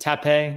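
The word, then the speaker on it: Tapé: You know, Syracuse did not end up getting, Patrick Tate, or is it Tape? – I assume it's Tape, because it's there's an accent on the E.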